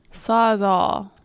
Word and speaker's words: Sawzall: How do I say that? – Sawzall? – Sawzall. – S-A-W-Z-A-L-L.